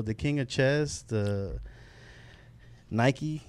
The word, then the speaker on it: Nike: Yeah, Nike actually means, it's pronounced Nike, and it's the goddess of victory.